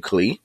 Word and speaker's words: Clea: When I saw her name online, it was C L E A. So I was like, is her name Clea? But then I heard people pronouncing, they said Clea. So I was like, oh, I didn't know that.